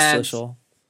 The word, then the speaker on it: Schlissel: And she spoke with Schlissel, I think that's how you say his name. – Yeah, President Schlissel.